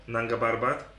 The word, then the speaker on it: Nanga Parbat: Więc na pewno wizja takiego dwutygodniowego treku, czy na przykład na Angahartap, to ta najwyższa góra. – Nanga Parbat. – Musisz się najpierw nauczyć wymawiać.